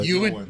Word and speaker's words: Ewan: Ewan.